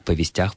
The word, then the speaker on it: повестях: Дело в том, что во множественном числе, в косвенных падежах, ударение в этом слове падает на окончание. Так же, как, например, в слове «ведомости», «ведомостях», «ведомостями», «повестях», «повестями».